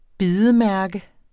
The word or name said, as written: bidemærke